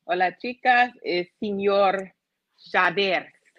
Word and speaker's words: Xavier: Señor Xavier. Got to say with the gotta roll your R's.